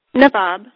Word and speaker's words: Navvab: Jill, one of the things that, and I don't want to butcher your name, Navvab? – Navvab. – Navvab. Okay.